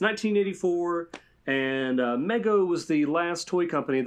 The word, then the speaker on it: Mego: I think it's pronounced Mego.